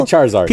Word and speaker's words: Charizard: Please don't pronounce it like that. – I know. Wait, Charizard? What do you say? – Well, you like your Charizard. – Charizard. – Just say Charizard.